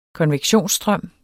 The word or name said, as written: konvektionsstrøm